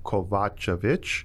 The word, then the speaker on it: Kovacevic: It's Kovacevic.